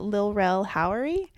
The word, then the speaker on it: Lil Rel Howery: I should have done more research, but Lil Rel Howery? – Oh, yeah. – Did I say his name right? – Yeah, Lil Rel, yeah.